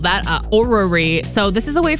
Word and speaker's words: orrery: So it's O-R-R-E-R-Y. Orrery. – It's Orrery.